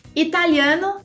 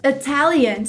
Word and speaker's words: Italian: Italiano, Italian. Você viu que o I tem o som de E, então mais uma vez. Italian. O francês é French.